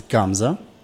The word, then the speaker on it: Gamza: Next, I want you to try pure Bulgarian indigenous varietal, which is Gamza. Gamza is also known as Kadarka. – Spell it. Gamza? – G-A-M-Z-A.